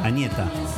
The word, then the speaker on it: Agnetha: Agnetha.